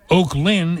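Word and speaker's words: Oaklyn: Oak Lynn is in Camden County, and it's O-A-K-L-Y-N. Not like Oakland.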